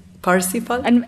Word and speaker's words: Parsifal: Parsifal? – Thank you.